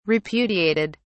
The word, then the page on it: repudiated: British English